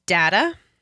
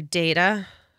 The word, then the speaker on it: data: Okay, Pinker's peeve that peeves me is data or data. People say that I say it wrong.